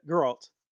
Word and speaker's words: Geralt: Yeah, Geralt. – Okay, because I don't know. You know, it's like GIF or JIF, like, you know, is it Geralt or Geralt? – I've been saying Geralt, so.